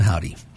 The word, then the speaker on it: Hovvdy: They spell their name H-O-V-V-D-Y. It's forming a W with the V's, but we're going to pronounce them Howdy.